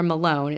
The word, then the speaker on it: Malone: On July 24th, Jay, I don't know if it's pronounced Maloney or Malone. It's M-A-L-O-N-E. Malone. – It sounds like Malone to me.